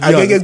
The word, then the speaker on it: Agege: Agege got 100,000 people.